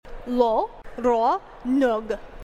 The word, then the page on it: lo ronuɣ: Phyrexian